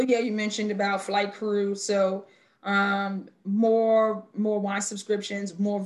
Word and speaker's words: cru: And it's crew spelled C-R-U, which is like the crew level in France.